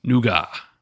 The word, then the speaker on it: nougat: Nougat is a French invention, and the correct pronunciation of it is nougat.